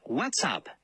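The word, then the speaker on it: What's Up: Se pronuncia WhatsApp. Es bastante simple, como decir What's y luego Up.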